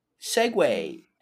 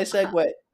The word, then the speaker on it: segue: Girlfriend, you know what will... rhymes with hey? segue. Ha. – That's how I segue.